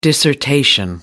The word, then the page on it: dissertation: American English